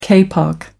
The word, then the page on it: kapok: American English